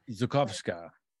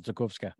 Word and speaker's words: Zakowska: And now, ladies and Jews, please welcome Donna Zakowska. Am I saying that correctly? – Yes. – Great. – Well, the W is really like a V. It's Zakowska. – Zakowska.